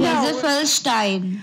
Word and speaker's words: Gesaffelstein: It's not a J. – What is it? It's gesaffelstein.